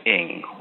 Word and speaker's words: Ng: The correct pronunciation is Ng, like jumping, writing, podcasting, not Ang, like Ang Lee or something like that.